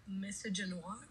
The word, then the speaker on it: misogynoir: So I think in the misogynoir, what is it? How do you say it? – Misogynoir.